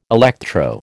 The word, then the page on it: electro: American English